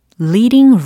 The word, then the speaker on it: leading: take a leading role. 네, 잘하셨습니다. 특히 reading, 읽기에 관련된 reading이 아니라 leading 이라고 발음을 하셔야 되겠죠. L-E-A-D-I-N-G 라는 철자입니다.